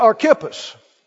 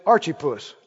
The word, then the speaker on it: Archippus: And say to Archippus, or as my friend Buddy Harrison used to say, Archipus. Tell old Archipus. What? Tell him what? Archipus? Take it. That's the Arkansas, Southern Missouri pronunciation.